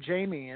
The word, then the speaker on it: Jamy: I guess just name him Amy with a J. So that's that's how I became Jamy.